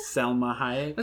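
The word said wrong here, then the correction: Salma Hayek: Okay, her name is Salma.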